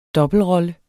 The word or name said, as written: dobbeltrolle